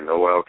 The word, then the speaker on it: Noel: It's like the Christmas song. – So it's Noel. – Yeah, Noel Calloway.